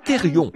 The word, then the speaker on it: therion: Ça se prononce peut-être Zon, Z-2-O-N. Comment le prononceriez-vous? Zoon? Je dirais Zoon. Et Thérion, c'est T-H-E-R-I-O-N, Thérion, voyez.